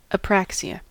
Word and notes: apraxia: American English; /əˈpræksɪə/

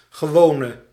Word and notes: normal: /ˈnɔː(ɹ)məl/

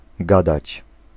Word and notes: twaddle: /ˈtwɒdəl/; /ˈtwɑdəl/

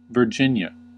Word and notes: Virginia: American English; [vɚ.ˈdʒɪn.jə]; /vɝˈdʒɪn.jə/

